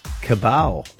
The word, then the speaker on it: cabal: He called them a cabal. Is that how you pronounce it? C-A-B-A-L? – Cabal, yeah.